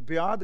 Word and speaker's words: ब्याद: महाराज शब्द वरत रहे आ कि देखो ब्याद उत्ते आप दर्शन करोगे, सिहारी नहीं पई थले हलंत पिया, इहदा मतलब इहदा उच्चारण ब्याद करना है, जिहनूं आद ब्याद ते उपाद आपां कहिंदे आ, तिन ताप।